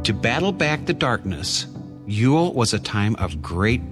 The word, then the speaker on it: geol: The word Yule, like so many of our Christmas traditions, comes from an old Anglo-Saxon word spelled G-E-O-L and pronounced, you guessed it, Yule.